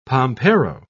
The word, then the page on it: pampero: British English